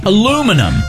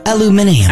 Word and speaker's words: aluminium: Aluminum. – I like the English pronunciation of aluminium. – Aluminium, which that's not even right. – It's not. – They add a syllable.